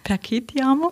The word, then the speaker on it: Perché Ti Amo: Wir hören jetzt gleich Ricci e Poveri mit Sara Perci Ti Amo. Habe ich das richtig ausgesprochen? – Perché Ti Amo? – Oh.